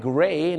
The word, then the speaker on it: gray: M-U-M ก็คือ mum สีเทาก็เหมือนกันครับ gray G-R-E-Y gray เนี่ยนะฮะชาวอเมริกาจะเป็น G-R-A-Y ก็เป็น gray นั่นเองครับอย่าลืมนะครับคำนี้ต้องออกเสียงรอเรือเพราะบางคนเนี่ยนะฮะเห็นฝรั่งใส่เสื้อสีเทามาไปบอกฝรั่งว่าโอ้ I like your gray shirt